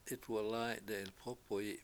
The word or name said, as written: 7itwalAy7 de7ilpOpoyi7